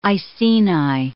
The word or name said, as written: Iceni